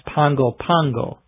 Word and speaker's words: Pago Pago: So I guess a true continuation of our series on American cities would just talk about Pago Pago, but we're going to talk about the entire area known as American Samoa. The capital of American Samoa can also be pronounced Pango Pango.